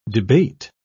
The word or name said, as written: debate